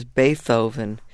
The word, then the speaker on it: Beethoven: But, you know, I remember my mother playing some music, and I remember her saying, this is Beethoven. She pronounced the TH, but at least she played it, you know.